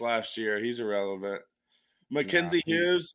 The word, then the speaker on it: Hughes: It's Huge. Hughes. Yeah, not Huge.